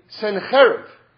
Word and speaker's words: Sennacherib: Basically, what happened was there was a war against the Jewish people, and it was done by one of history's great generals named Sennacherib. Okay? That's how you say it in Hebrew anyway.